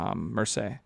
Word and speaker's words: Marseille: And he currently is employed by Arsenal, but he's on loan at Marseille in France. And wow, I didn't know how to pronounce that that's until you just said it. Marseille, huh? – Yeah. – Because it looks like Marcellus.